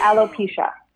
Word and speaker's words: alopecia: You have a diagnosis of alopecia. Wait, did I say it wrong already? Alopecia. – No, you're good. It's alopecia.